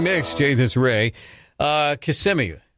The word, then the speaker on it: Kissimmee: Well, it's Kissimmee, at least according to radio down there. – That's a good one. That's a good one. – Driving through Kissimmee, and I wasn't sure how to pronounce it, and I heard an ad on the radio.